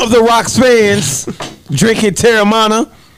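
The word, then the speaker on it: Teremana: Terramana. – That's probably not right, but. – It is Terramana, nigga.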